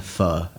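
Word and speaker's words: Phở: Ich kann immer, zu jeder Tageszeit, kann ich eine vietnamesische Nudelsuppe essen, die spricht man aus, eine Pho. Also P-H-O-O geschrieben, eine Pho.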